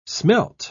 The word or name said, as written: smelt